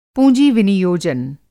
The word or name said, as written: पूँजी-विनियोजन